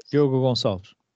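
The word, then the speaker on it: Gonçalves: And another player, which you're going to have to help me out with the pronunciation, Diogo Goncalves? – Diogo Goncalves.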